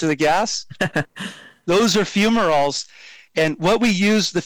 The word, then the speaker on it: fumaroles: So how is that pronounced? – Oh, yes. No problem. So they're called fumaroles.